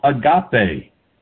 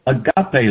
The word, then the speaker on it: agape: It's called agape, A-G-A-P, long E, agape love.